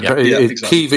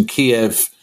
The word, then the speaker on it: Kyiv: And Kyiv is the Ukrainian pronunciation. Kiev, as in the chicken, is the Russian pronunciation.